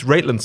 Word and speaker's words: Raitlin: It's Raitlin spelled like Caitlin. So I'm going to go with Raitlin.